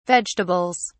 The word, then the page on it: vegetables: British English